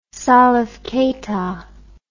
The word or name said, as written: salif keita